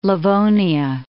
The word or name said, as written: Livonia